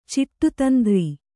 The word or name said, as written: ಚಿಟ್ಟುತಂತ್ರೆ